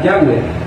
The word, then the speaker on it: Yahweh: Ese tetragramatón tenía una forma fonética, y según los eruditos hoy en día, la mejor forma de leer el tetragramatón era Yahweh.